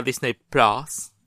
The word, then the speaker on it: Disney Plus: Resulta que esto es una noticia un poco chorra, que es que ahora Disney Plus, porque aquí en España se dice Disney Plus, que decía Berto el otro día en Leitmotiv. – ¿Así es como se dice? – Sí. Se tiene que pronunciar todas las letras y el más es plus. Porque es como X-Men.